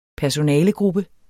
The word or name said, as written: personalegruppe